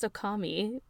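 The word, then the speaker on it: Okami (女将): And Yuki's like, it's pronounced Okami, which is really funny. This is the joke that took us a long time to discuss mm-hmm. previously, which is yeah. a, just a pun on the word okami. Like Momiji basically pronounces it wrong.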